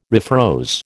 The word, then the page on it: refroze: American English